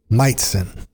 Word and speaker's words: Majcen: And digging what I'm hearing, Meitzen, his last name, a little bit tricky. So if you're looking for it, wherever you get your music, M-A-J-C-E-N. But from what I understand, it's pronounced Meitzen.